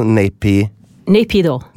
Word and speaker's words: Naypyidaw: Men det altså Naypyidaw. Sånn uttalelse.